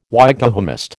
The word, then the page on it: Wykehamist: American English